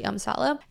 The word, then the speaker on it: Amsale: And Amsala is actually spelled as A-M-S-A-L-E. So you would think it's pronounced Amsale, but it's actually Amsala.